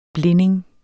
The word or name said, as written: blinding